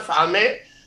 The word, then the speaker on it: Mfalme: And she spelled it M-F-A-L-M-E. Some people call it Falme, and some people pronounce the M slightly, Mfalme.